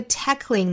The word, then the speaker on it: tackling: 后面这个 tackling 呢，本身是 tackle 这个词，后面加了 ing，我们注意音节其实是不多加一个的，还是两音节的词，读成 tackling，大家不要读成了 tackling，在 k 和 ling 之间没有其他的元音，在发这个词的时候一定要注意在爆破音的后面不要拖出其他的元音来，这个词读 tackling together we're tackling this disease。